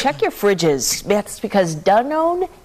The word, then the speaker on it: Danone: That's because Danone is recalling its all of Danone. That's the way it's pronounced. – I was always saying Dannon.